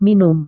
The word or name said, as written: minum